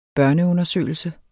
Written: børneundersøgelse

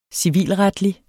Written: civilretlig

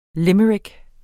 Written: limerick

